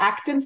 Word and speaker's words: Acton: and they're called the Acton schools.